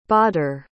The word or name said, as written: badr